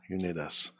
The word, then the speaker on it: Unidas: And pronounced Sirius Unidas. – Unidas. Okay, it's time to cut our cigar.